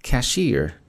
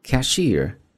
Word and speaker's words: cashier: Cashier, 注意收银员读作 cashier, cashier, 这么去读。